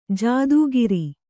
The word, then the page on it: ಜಾತಿಕಾರ: jāti kāra